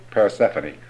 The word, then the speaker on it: Persephone: Persephone? – Persephone.